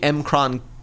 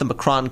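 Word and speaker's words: M'Kraan: And, you know, he has decided to steal this thing called the M-Kron crystal, not the Macron crystal, as it's been pronounced in other media I've seen. – Yeah. – You pronounce it M-Kron.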